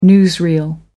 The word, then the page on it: newsreel: American English